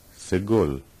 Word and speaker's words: Segol: Segol is pronounced like the E in met.